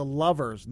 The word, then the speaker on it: Lovers: First one is from Lulu and the Louvers. Did I do that correctly? It's the Lovers, not the Louvers, the way it's spelled. It's Lulu and the Lovers.